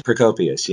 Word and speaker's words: Procopius: Procopius of Caesarea? – Is that yes, Procopius, yes.